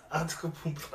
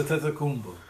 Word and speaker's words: Antetokounmpo: But next one on the list, Giannis Antetokounmpo. I think I said it right. – Giannis Antetokounmpo. Oh, shit. – Giannis Antetokounmpo.